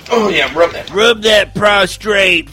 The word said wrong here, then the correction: prostate: First of all, it's prostate. Prostate. Prostrate is an entirely different thing.